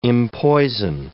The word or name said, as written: empoison